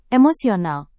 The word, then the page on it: emosional: indonesian